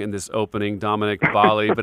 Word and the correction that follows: Balli: but it's actually Dominic Balli, as in Cali, correct? – Balli like Cali. That is correct.